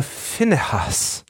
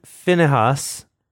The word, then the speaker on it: Phinehas: Moses sent them, 1,000 of every tribe, to the war, them and Phinehas, the son of Eleazar the priest. – Oh, I guess it is Phinehas, not Phinehas. – Maybe it's pronounced like a fanny, but a finny and a has.